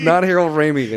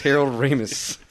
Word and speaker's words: Harold Ramis: It's not Harold Ramey. It's Harold Ramis.